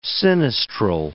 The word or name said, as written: sinistral